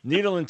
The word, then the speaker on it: Nederland: Is that how you pronounce it, or is it Nederland? – Nederland. – Sorry. Yeah, it's not land. It's Nederland. Nederland, Texas.